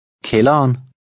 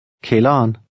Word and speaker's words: kælderen: With this in mind, try to read number 9, where the D is also silent. – Kælderen. Kælderen.